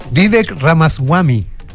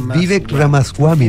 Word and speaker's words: Vivek Ramaswamy: Vivek Ramaswamy. Vive Vivek Ramaswamy.